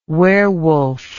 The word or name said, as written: werwolf